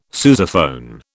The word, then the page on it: sousaphone: British English